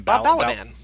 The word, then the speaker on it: Balaban: Bob Balaban.